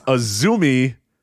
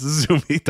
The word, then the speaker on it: Azumi: It's Azumi, AZM, of course. It's spelled AZM, but it's Azumi. Don't ask.